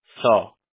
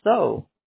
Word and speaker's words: Seo: Don't laugh at me when I say this, because yeah, it's spelled S-E-O, but it's pronounced So.